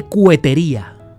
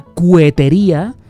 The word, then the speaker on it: cohetería: La palabra de hoy es andar de cuetería. Aunque suena cuetería, se escribe cohetería y es un término que cada vez se oye menos.